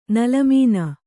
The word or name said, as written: ನಿಲ್ಲುಮನೆ